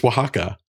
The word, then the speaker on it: Oaxaca: So like seeing Bryce trying to figure out how to pronounce Oaxaca, which seems so just normal to me. I just expect everyone to just know that it's Oaxaca.